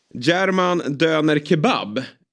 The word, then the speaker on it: German Döner Kebab: Eh, men framförallt då eh, är han sedan i somras eh, ägare av restaurangen German Döner Kebab. Uttalade jag det rätt där? Det lät helt okej.